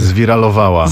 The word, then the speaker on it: zwiralowała: Viral, zwirasowała się. – Zwiralowała. – Zwiralowała, oh, even better, yes.